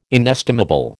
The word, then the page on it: inestimable: American English